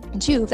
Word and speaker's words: Joovv: Juve, that's spelled J-O-O-V-V, it